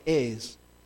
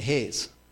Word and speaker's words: heirs: And as a result, we are heirs. Some people say heirs. They're heirs. Silent H. Like P in Bath.